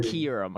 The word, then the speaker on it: Kyurem: Apparently, it's pronounced Kyurem according to House Stark.